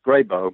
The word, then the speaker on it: Grebo: The two groups that they encountered, the two different places, is it the Grebo? Is that, am I pronouncing that correctly? – Yes, Grebo. Mm-hmm.